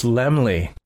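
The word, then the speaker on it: Laemmle: How do you do, Mr. Carl it's Lemley. I was saying it wrong the whole time.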